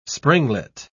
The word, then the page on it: springlet: British English